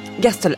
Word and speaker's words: Gerstl: Alors l'année suivante, en 1906, Schönberg fait la rencontre d'un jeune peintre, je ne sais pas si je veux bien prononcer son nom, Richard Gerst. – Gerstl, absolument.